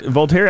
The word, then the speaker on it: Voltaire: I know I said that right. Voltaire. – Okay, I wasn't going to argue with you. – Fucking asshole. – In fact, Voltaire